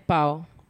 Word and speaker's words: Pau: Depende de dónde está el acento. No tiene tilde. Será ah, entonces Pau. – Pau. que Pau. – Es Pau.